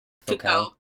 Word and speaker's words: Foucault: And Shaking the Habitual takes its title from a quote by French philosopher Michel Foucault. Is that how you pronounce it? – Foucault.